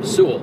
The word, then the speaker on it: Sewell: Sewell.